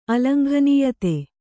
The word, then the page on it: ಅಲಂಘನೀಯತೆ: kannada